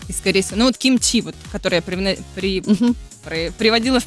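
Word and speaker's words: Кимчи (김치): Вот, например, в том же кимчи вообще оно произносится с легким придыханием. Кимчи.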